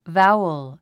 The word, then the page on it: vowel: váuəl; バァウォ